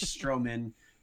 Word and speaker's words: Strohman: I always expect there's an extra syllable, but Mitch Strohman, the NAU play-by-play.